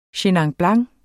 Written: chenin blanc

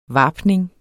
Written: varpning

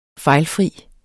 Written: fejlfri

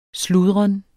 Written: sludren

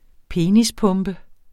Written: penispumpe

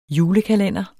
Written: julekalender

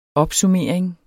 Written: opsummering

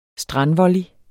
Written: strandvolley

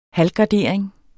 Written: halvgardering

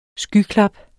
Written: skyklap

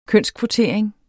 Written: kønskvotering

